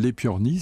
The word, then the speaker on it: Aepyornis: Cet oiseau s'appelle l'épiornis. Ça s'écrit a e P-Y-O-R-N-I-S.